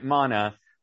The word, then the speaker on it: Manna: And the film is called Camp Manna uh, ma, uh, mana, if you pronounce it right in Aramaic and Hebrew, it's manna.